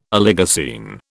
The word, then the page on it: Oligocene: British English